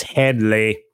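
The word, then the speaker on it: Hedley: No, I mean, you said it right, but... – Oh, I thought you were correcting me. – No, I just went, it's Headley.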